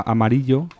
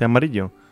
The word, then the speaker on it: Amarillo: O sea, ¿no se dice amarillo? – No. Bueno, tú puedes decir amarillo. – Yo puedo decirlo como... – Claro. Pero allí dicen Amarillo.